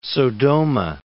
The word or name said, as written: Sodoma